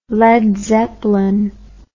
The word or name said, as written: Led Zeppelin